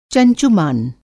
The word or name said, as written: चंचुमान